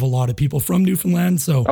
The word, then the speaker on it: Newfoundland: And I also love that you pronounced Newfoundland correctly. That sounds so good to hear. – How do people normally... – Newfoundland, we get Newfoundland. Newfoundland and Newfoundland, and I can't blame them because it's not how you think it'd be pronounced, but it's really refreshing when I hear it pronounced correctly, so thank you.